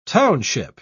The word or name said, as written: township